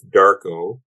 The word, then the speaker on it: DVRKO: And then he's got this song with Darko, D-V-R-K-O.